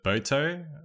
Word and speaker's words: Boto: So you've got the Amazon River Dolphin, Pink Dolphin, and then the Boto, I think. Is that how you pronounce correct. – It? – That's correct. Yes, exactly.